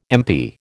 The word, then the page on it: MP: American English